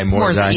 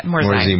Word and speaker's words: Morsi: that's how we pronounce his name, right? M-O-R-S-I, Morzai. – Yeah, Morzai.